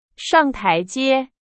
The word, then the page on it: 台阶: Chinese